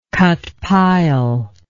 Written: cut pile

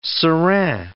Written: serin

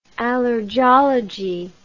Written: allergology

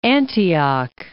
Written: Antioch

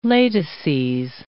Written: latices

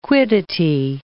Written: quiddity

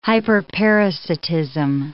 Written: hyperparasitism

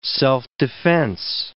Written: self-defense

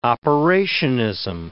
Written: operationism

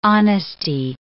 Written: honesty

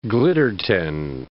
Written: Glittertind

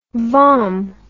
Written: vom

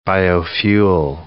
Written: biofuel

